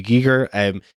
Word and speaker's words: Giger: Giger. His name is not Geiger. The reason people think it's Geiger is because it was mispronounced at the 1980 Oscars.